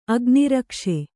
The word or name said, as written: ಅಗ್ನಿರಕ್ಷೆ